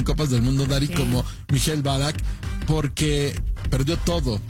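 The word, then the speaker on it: Michael Ballack: ¿Cómo se dice bien? ¿Mikhail Balak? – Mikhail Balak, sí.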